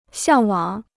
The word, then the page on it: 向往: xiàng wǎng